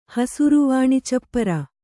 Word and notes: ಹಸರುವಾಣಿಚಪ್ಪರ: hasaru vāṇi capara

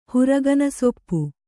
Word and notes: ಹುರಗನಸೊಪ್ಪು: Kannada

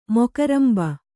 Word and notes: ಮೊಕರಂಬೆ: mokarambe

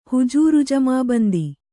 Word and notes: ಹುಜೂರುಜಮಾಬಂದಿ: hujūru jamābandi

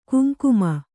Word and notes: ಕುಂಕುಮ: kuŋkuma

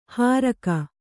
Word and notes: ಹಾರಕ: hāraka

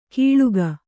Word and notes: ಕೀಳುಗ: kīḷuga

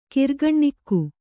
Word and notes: ಕಿರ್ಗಣ್ಣಿಕ್ಕು: kirgaṇṇikku

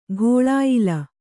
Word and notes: ಘೋಳಾಯ್ಲ: ghōḷāyla

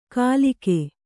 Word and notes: ಕಲಿಕೆ: kalike

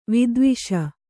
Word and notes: ವಿದ್ವೇಷಿ: vidvēṣi

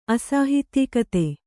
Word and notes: ಅಸಾಹಿತ್ಯಿಕತೆ: Kannada

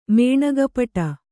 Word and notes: ಮೇಣಗಪ್ಪಡ: mēṇagappaḍa

